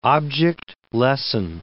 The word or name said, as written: object lesson